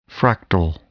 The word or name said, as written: fractal